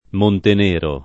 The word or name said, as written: Montenero